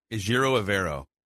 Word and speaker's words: Ejiro Evero: Ejiro Evero.